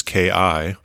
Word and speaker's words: ki: So what Dr. Kimmerer proposes is that the last two letters of this word, which is K-I, or pronounced ki, would signify a being of the living earth.